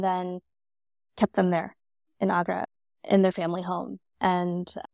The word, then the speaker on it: Agra: Okay, Escaping Agra.